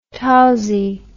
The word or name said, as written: tousy